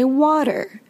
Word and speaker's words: water: Some English speakers in other countries say water. Americans say water. And when you say water, you notice that your tongue is flapping at the top of your mouth.